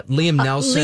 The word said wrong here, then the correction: Neeson: Liam Neeson. Yes. – Neeson. Oh gosh, I said that wrong.